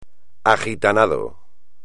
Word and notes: agitanado: espagnol

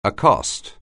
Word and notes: accost: anglais

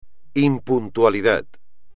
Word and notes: impuntualidad: espagnol

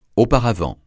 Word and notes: auparavant: francais

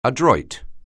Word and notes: adroit: anglais

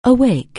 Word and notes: awake: anglais

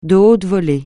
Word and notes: de haute volée: francais

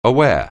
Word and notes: aware: anglais

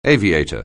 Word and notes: aviator: anglais

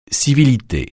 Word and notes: civilité: francais

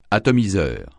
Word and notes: atomiseur: francais

